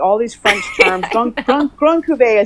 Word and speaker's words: Cuvée: Because there's like Brut, there's Cuvée.